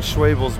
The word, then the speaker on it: Schwebel's: There's a bread company called Schwebel's Bread. And I've in Texas? – It's in Ohio, and they've been saying Schwebel, and I was like, man, I've been saying it wrong all these years.